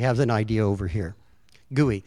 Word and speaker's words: GUI: G-U-I, and it is pronounced gooey.